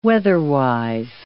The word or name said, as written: weather-wise